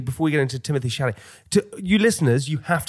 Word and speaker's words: Chalamet: Do you know Timothy Chalamet? – Is that how you say his name? – Timothy Chalamet.